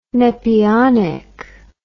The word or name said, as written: nepionic